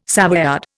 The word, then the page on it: Savoyard: Indian